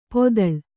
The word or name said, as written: ಪೊದಳ್